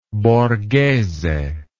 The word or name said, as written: Borghese